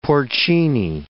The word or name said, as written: porcini